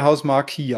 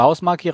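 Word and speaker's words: Housemarque: Werden die jetzt Hausmark oder Hausmarkie ausgesprochen? Ja, ich sag, mir rutscht immer Hausmarkie raus, aber ich glaube, sie heißen Hausmark.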